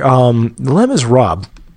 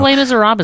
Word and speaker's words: Les Misérables: But for Les Misérables, I know we're not even pronouncing that name right. – I think Les Misérables is correct. – No, that's how Americans pronounce it. – I think it's actually Les Miserables. It's got that little bit of that French blood.